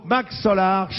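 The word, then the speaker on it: MC Solaar: le groupe Max Solar chante « Bouge de là ». – Max Solar, logique, ça s'écrit MC comme McCartney.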